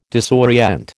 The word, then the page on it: disorient: American English